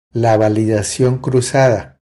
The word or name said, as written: La validacion cruzada